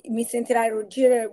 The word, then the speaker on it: Mi sentirai ruggire: So in Italian, it's called Mi Sentirai Ruggire, which means you're going to hear me roar.